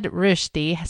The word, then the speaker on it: Rösti: Now, you said Rösti.